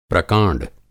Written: प्रकाँड

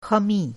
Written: ख़मी

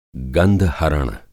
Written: गंधहरण

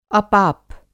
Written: अपाप

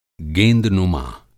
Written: गेंदनुमा

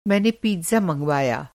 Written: मँगवाना

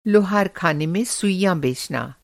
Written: लुहार

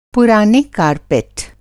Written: कार्पेट

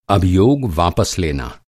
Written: अभियोग

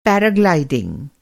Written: पैराग्लाइडिंग

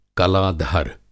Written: कला-धर

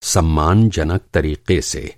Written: सम्मानजनक

